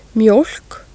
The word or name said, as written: mjoelk